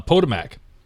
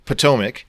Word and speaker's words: Potomac: Potomac. – Potomac.